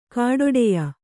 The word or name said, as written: ಖಡ್ಡಾಯ